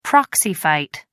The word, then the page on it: proxy fight: American English